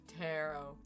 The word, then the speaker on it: tarot: Oh my god, or somebody said tarot one too many times. It's pronounced taro. To all of our listeners, it's taro. And if you're sitting here like, bitch, no, it's taro.